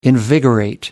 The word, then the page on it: invigorate: American English